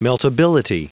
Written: meltability

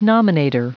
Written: nominator

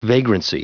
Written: vagrancy

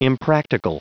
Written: impractical

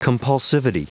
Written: compulsivity

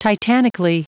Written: titanically

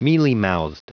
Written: mealymouthed

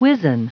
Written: wizen

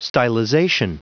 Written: stylization

